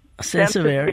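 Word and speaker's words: Sansevieria: Is that how you say it? – Sensiviera, yeah, right.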